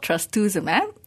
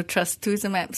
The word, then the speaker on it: trastuzumab: It's called trastuzumab. – Okay. – So trastuzumab.